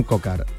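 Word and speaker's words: Coquard: Kokuar, el... seguramente no se dice así, pido disculpas, el ciclista francés. – Brian Cocard, que es precisamente el que está hablando, que imagino que no sé si se le entiende o no.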